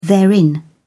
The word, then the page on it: therein: British English